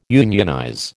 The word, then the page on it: unionize: American English